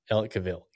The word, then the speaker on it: Ellicottville: Tell us the ski town and the market itself, Ellicottville, New York, right? – Did I pronounce it right? Yeah, Ellicottville, New York. – Ellicottville. Okay, perfect.